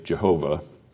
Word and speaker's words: Jehovah: Yahweh, Jehovah, I asked a professor, what's the right pronunciation of that four letter Hebrew word? And he said, well, about half of people say Yahweh and about the other half say Jehovah. But it's that Yahweh.